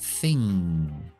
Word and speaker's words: thing: Ahí hay otra N, cuando hay una NG en inglés, siempre pronunciamos esa. Por ejemplo, en la palabra cosa. Mm, thing. Thing. Diferenciamos delgado, thin. Thin. Cosa.